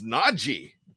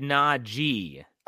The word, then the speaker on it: Najee: You said Najee. – I said Najee. – That's how he wants it pronounced, not Najee. He wants it Najee. It's not Najee. – It's not a Z sound in there.